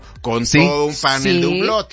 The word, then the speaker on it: Hublot: ¿Cómo se pronuncia esa marca? – Hublot.